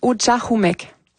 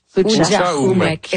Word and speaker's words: Utchahumek: On le prononce Utchahumek. – Utchahumek.